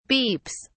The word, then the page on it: beeps: British English